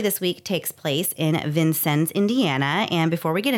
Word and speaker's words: Vincennes: So we think we're pretty confident it's Vincennes, but if you Google it, you will get four different pronunciations. So we went to the source, to the people, and they said Vincennes.